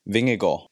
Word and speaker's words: Vingegaard: Just to make it clear, how do you pronounce second name? Vingegaard? – Now you have to learn Danish. It's Vingegaard.